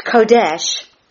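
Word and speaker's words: Kodesh (חודש): It's Kodesh, K-H-O, emphasis on the K-H-O, hyphen D-E-S-H.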